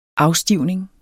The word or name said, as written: afstivning